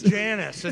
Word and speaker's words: Janus: It's Janus. It's not Janus.